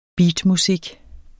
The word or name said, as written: beatmusik